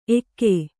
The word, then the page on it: ಎಕ್ಕು: Kannada